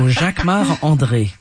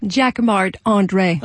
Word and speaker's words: Jacquemart-André: You say it like André. – A Frenchman. Jacquemart-André. – Oh, it's because I'm French. So Jacquemart-André.